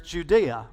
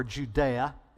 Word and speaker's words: Judea: Okay, but then there's Judea, or Judea, depending on how you choose to pronounce it.